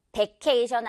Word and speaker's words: vacation: 놀러 가는 거 다들 좋아하는데, 이것도 역시 vacation 아니고, V 발음 살려줄게요. Vacation. 왜, 왜. 토끼 이빨에 핸드폰 진동 있죠? 휴대폰? Vacation. Alright? 자, 요거 그리고 왜보다 약간 조금 더 왜에 가깝게 발음해 보세요.